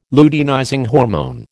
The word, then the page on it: luteinizing hormone: American English